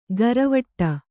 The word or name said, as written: ಘರವಟ್ಟ